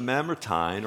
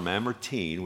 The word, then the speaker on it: Mamertine: And Paul writes this probably about AD 67, and he's writing it from Rome, and he's in the Mamertine or Mamertine, whichever pronunciation you prefer, prison.